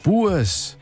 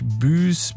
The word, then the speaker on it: Buess: Bues. Gut, das schreibt sich B-U-E-S-S. – Genau. – Jetzt sagen die allermeisten natürlich Büs, Büs oder Büsse, sowas.